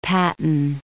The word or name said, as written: Patton